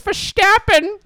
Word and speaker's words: Verstappen: You have to say it like this. And it's Verstappen.